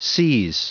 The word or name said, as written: seize